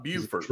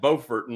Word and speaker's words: Beaufort: And for all you South Carolinians, y'all are saying it wrong. It's not Beaufort, although it's spelled the same. It is Beaufort, North Carolina.